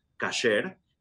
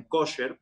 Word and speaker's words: kosher: O Bueno, la comida kasher o kosher, bien mencionado por ti, eh, los judíos que venimos de España le decimos a la comida apta para la alimentación de acuerdo al judaísmo, le decimos kasher. Y los judíos europeos, ya sabes que pronuncian un poquito diferente el hebreo, le dicen kosher,